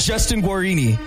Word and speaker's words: Guarini: Justin Guarini.